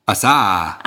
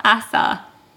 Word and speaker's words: Asa: Asa. – Asa. Asa. – NASA minus the N.